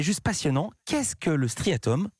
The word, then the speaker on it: striatum: Il montre le cortex et aussi une autre partie, ça s'appelle le striatum. Je prononce bien ? – Oui. Vous avez vraiment tout compris. – Le striatum. – C'est vraiment ça.